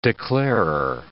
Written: declarer

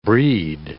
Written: brede

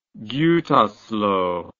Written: Gutersloh

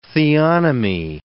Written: theonomy

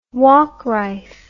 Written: waukrife

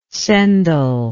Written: cendal

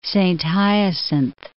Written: Saint-Hyacinthe

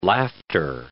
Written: laughter

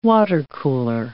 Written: watercooler

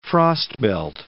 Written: Frostbelt

